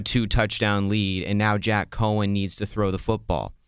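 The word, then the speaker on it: Coan: Is that how you say his name? Cohen. Yeah, Jack Cohen, quarterback Jack Cohen for Wisconsin.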